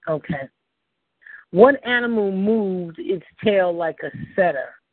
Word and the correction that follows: cedar: Am I pronouncing that right? Setter, cedar, C E D A R. A cedar. Cedar.